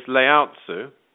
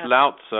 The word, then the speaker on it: Lao Tzu: So the next cosmic avatar I was going to mention is Lao Tzu, pronounced Lao Tzu, I'm reliably informed, or something nearer to that than what I said before.